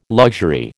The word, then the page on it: luxury: American English